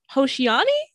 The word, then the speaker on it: Hoshiani: So congratulations on your upcoming album, Hoshiani. Did I pronounce that properly? – So I keep sending this out. Yeah, that is properly.